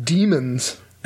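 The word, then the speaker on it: daemon: Is Greek word for spirit. It's even oh, spelled, really? – Yeah, it's even spelled like the Greek word. Well, that makes sense. D a D a e m o n. He's anglicized to that.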